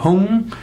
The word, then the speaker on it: Hum: Da wird eben aus Padme wird Peme und aus Hum wird Hung.